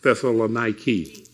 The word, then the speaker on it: Thessaloniki: I know they say it's pronounced Thessaloniki.